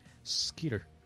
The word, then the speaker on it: Skeeter: Skeeter.